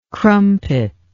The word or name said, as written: crumpet